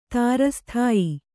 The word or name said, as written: ತಾರಸ್ಥಾಯಿ